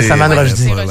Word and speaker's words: Salman Rushdie: Tu veux dire Salman Rushdie? – Je ne sais pas quelle prononciation. – C'est Salman Rushdie.